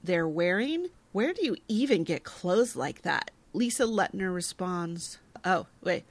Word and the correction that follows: Lisa Lutner: It's Lisa Lutner. – I was awooga-ing Lisa Lutner. Thank you for the help with pronunciation.